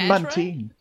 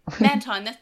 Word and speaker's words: Mantine: It's called Mantine. – Yeah. – Or Mantine. – Wait, Mantine, that's right.